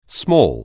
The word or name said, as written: small